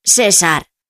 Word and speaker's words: César: Claro, porque en Hispanoamérica seseáis y decís César.